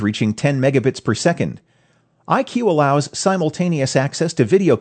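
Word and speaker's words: IKU: It's pronounced IQ, but it's spelled I-KU, as in Ku-band.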